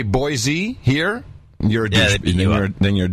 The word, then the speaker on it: Boise: That is the proper pronunciation, by the way. – Boise? – Boise, yes. – So you pronounce it kind of with an S.